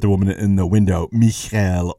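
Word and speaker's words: Michal: I was raised a low-church Protestant, and we always pronounced her name Michael. I realize in the Hebrew it's Michelle, and I realize that that probably is the preferred pronunciation of her name, but I can't help myself. My Baptist roots are showing through,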